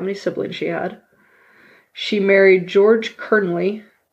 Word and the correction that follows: Kennerly: Uh, Oh, sorry, I said that wrong. Kennerly in 1830 in Maryland.